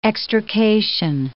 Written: extrication

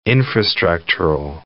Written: infrastructural

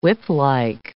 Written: whiplike

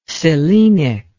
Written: selenic

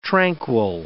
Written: tranquil

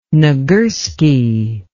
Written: nagurski

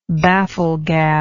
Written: bafflegabber

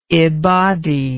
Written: ibadi

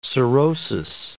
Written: cirrhosis

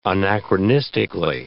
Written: anachronistically

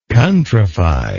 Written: countrify

